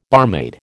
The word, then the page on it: barmaid: American English